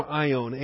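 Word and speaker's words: Aion (αἰών): All right, the vocabulary you want to focus on, and really it's the vocabulary for our whole study today, is the term Aion, A-I-O-N. A-I-O-N, it's the long O, it's the Omega O there. A-I-O-N, number 165 in the Strong's Index.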